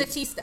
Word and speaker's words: Statista: So, bottom line is, according to a 2018 report, am I saying this right? Statia? Stati- Statista. Statista. – Yes. Like Batista.